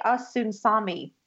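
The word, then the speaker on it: Osunsanmi: But I also wanted to point out that this was directed by Olatunde Osunan. Oh, let me say that again. Olatunde Osunsami. Ooh, I said it right.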